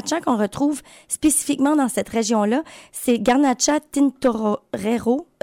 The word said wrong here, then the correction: Tintorera: Pardon. Garnacha Tintorera.